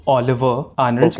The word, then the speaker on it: Ólafur Arnalds: The pronunciation is Oliver Arnalds, okay. because he's from Iceland. He's an Icelandic musician.